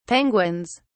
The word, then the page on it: penguins: British English